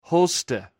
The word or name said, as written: hwste